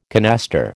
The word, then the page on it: canaster: American English